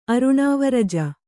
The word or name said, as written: ಅರುಣಾವರಜ